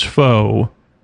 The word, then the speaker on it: Faux: How lucky did I just get that one of my favorite words, Faux, rhymes with Cousteau?